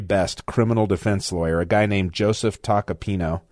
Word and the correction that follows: Tacopina: I'm sorry, Tacopina, I believe is the pronunciation.